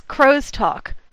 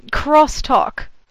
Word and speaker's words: crosstalk: Now, Jaws says this crow's talk. I have pronounced it crow's talk when giving a talk on it. – So if the cable's expensive, is it cabling? – Cabling. Yeah. So, crosstalk. Don't do that.